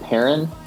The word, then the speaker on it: Perrin: So my Twitter handle is at the letter G, Luke Perrin. Perrin is spelled P-E-R-R-I-N.